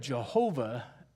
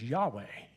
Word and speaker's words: Yahweh: instead of Jehovah God, the correct Hebrew pronunciation was Yahweh, and he kept referring to Yahweh.